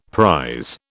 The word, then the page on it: prise: British English